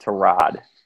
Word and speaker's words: Tyrod: Let me correct you. It's actually pronounced Tyrod.